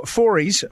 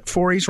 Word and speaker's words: Forys: And so we have with us Matthew Forres. Forres rhymes with stories. He told me to get this right.